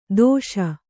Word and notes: ತೋಷ: tōṣa

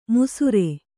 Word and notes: ಮುಸುರೆ: musuṛe

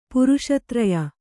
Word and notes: ಪುರುಷತ್ರಯ: puruṣa traya

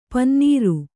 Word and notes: ಪನಿನೀರು: Kannada

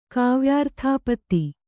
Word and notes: ಕಾವ್ಯಾರ್ಥಾಪತ್ತಿ: Kannada

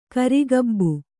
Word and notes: ಕಿರುಗಬ್ಬ: kannada

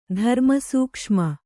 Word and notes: ಧರ್ಮಸೂಕ್ಷ್ಮ: dharma sūkṣma

